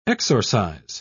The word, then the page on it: exorcize: British English